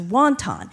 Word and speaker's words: wonton: We call wonton soup wonton because the first immigrants that came here to the United States were Cantonese, and they pronounce that dumpling as wonton.